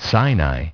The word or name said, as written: Sinai